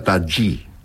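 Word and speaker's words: Tajy: Se escribe Tají con J, Y, pero me han dicho que se pronuncia Tají.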